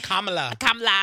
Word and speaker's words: Kamala: Kamala. – I've been practicing. Y'all better put the comma in the love. Okay. Yeah. All right, my girl, Kamala.